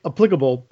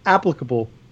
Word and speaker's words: applicable: Because I feel like I like to say it applicable, but you say applicable. And tomato, tomato, I don't know. Applicable, maybe. I don't know. – I think you're putting the emphasis on the wrong syllable.